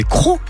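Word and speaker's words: Crots: C'est marrant, vous dites les Crocs, mais c'est Crocs tout court, hein, la commune de Crocs. D'ailleurs, ça s'écrit Crotz, mais on dit Crocs.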